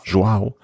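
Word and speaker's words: João: Joao.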